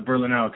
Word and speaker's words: Berlinale: And now you took classes at, is it called, pronounced Barnall? Barnall? the talent campus? Did you take classes out there too recently? – Oh, yeah, the Berlinale talent campus.